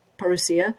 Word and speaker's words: Parousia: Well, um, I'm happy to get acquainted with you and your audience, and um, I love your name, Parousia, or uh, the Greek pronunciation is Parousia.